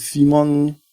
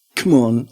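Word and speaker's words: CMON: Das wird mit Simon oder wie laut Jan, der extra nachgefragt hat, das wird Come On ausgesprochen.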